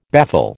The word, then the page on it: baffle: American English